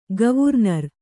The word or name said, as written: ಗವರ್ನರ್